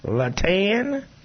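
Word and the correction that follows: Iatan: It's Ayatan. – Ayatan. Plant.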